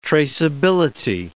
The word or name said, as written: traceability